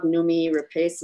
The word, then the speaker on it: Noomi Rapace: I love Noomi Rapace.